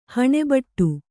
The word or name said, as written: ಹಣೆಬಟ್ಟು